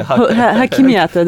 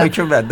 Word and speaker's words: Хокимията: Нет, вы знаете, я очень хотела встречаться с представителями именно Хокимията, исполнительной власти. – Вы правильно сказали. – Хокимията, да? – Да. – Хокимията, да, правильно.